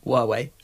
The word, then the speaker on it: Huawei: I think next to the Hawaii, what's that new one? P30 Pro. But Huawei.